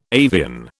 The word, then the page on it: avian: British English